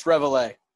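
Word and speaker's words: Reveille: Oh, it's Revele. I looked it up. – I don't know how to say it. Revele? I thought it was Revele. – Yes, it's Revele.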